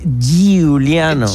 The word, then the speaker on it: Giuliano: Juliano. – El normal, ¿en qué equipo está? – En el Atlético de Madrid. – Está ahí, el papá lo tiene encima. – Pero lo tenés que pronunciar así, Giuliano.